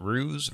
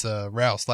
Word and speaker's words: Rouse: It's Rouse, like house. That's how you say it.